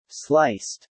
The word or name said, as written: sliced